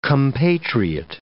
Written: compatriot

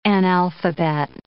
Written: analphabet

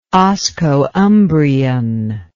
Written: oscoumbrian

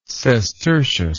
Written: sestertius

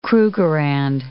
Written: Krugerrand

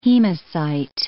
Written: hemocyte

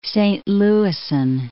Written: Saint Louisan

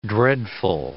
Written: dreadful

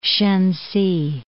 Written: Shensi

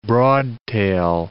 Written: broadtail

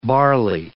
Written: barley

